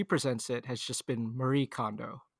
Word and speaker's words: Marie Kondo: But I did notice some people calling out that the how you would actually pronounce her name is Marie Kondo. It's a little bit different, but you know, we just want to be respectful toward that.